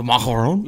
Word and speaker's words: macaron: In American, I say the macaron, which is actually not a valid pronunciation of it at all. It is macaron.